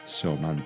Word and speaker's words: 小満: それが終わって今昭満っていうのがね始まっていますあらゆる生命が満ち満ちてくる時期だから満ちる満足の満と書いて、えー、小さな満足小満と読むんですね、